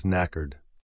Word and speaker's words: knackered: A silent K begins this British slang word meaning very tired. The answer is knackered.